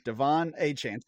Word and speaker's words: Achane: I did see a note from Schefter, from Adam Schefter, earlier today that after the game, that the running back noted that his name should be pronounced Devon Achane. – Okay. – Devon Achane. So that's going to take some getting used to. Not Achane, but Achane. So he's earned it.